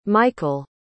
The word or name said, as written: micol